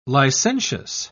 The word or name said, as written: licentious